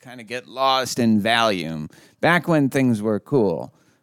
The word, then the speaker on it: Valium: Yes, it absolutely is Valium.